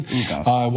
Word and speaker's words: Ugas: How do you pronounce his last name? – Ugas. Cuban. – Ugas.